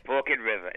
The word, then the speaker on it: Forked River: F-O-R-K-E-D and then river, and they pronounce it Forked. – Forked? – Yeah. – Do they? – Forked River. It's not my accent. That's how they say it.